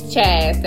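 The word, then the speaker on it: Chazz: If you don't know my name is Chaz. That's spelled like jazz. C-H-A-Z-Z. 2 Z's. Don't ever forget the 2 Z's.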